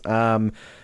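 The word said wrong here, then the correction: Ngāruawāhia: Help me here. – Narawahia, which is just down the road from where I live here in the Waikato.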